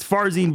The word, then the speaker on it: Farzin: By the way, someone was asking in the chat while I was talking to Charlie there, Casey asking, how do you pronounce your first and last name? I love when I get this question. It's Farzin Vesugian. That is how you say it.